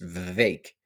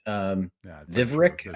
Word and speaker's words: Vivek: One is, one of the learnings that we had is that his name is pronounced Vivek, which we learned when we were there at his event. I thought it was Vivek. Doug, you kept pronouncing it with an R in it. Vivrek, I think you kept saying.